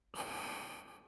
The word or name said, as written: 承办